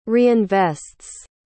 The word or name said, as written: reinvests